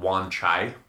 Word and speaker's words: Wan Chai: Well, anyway, we'd become friends, and he brought me down to the car dealership in a place called Wan Chai. It's spelled W-A-N-C-H-A-I.